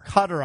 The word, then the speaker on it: Qatar: Is it pronounced Cutter or Qatar? – Cutter, I believe, is the correct pronunciation of the Middle Eastern country.